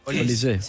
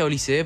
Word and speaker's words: Olise: Olise. – Él lo pronuncia Olise, pero creo que se pronuncia Olis.